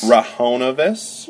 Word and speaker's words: Rahonavis: Ravenous. – Rahonavis. – Rahonavis. Velociraptor. – No, Microraptor. – Microraptor. And Archie is A-R-C-H-A-E.